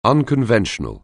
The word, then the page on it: unconventional: anglais